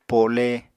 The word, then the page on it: po7le: Apatani